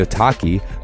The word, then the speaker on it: Otaki: And the Otaki, rhymes with souvlaki, is an aggressive high-performance shoe designed to hold the downturn shape even after repeated lashings.